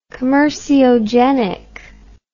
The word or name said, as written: commerciogenic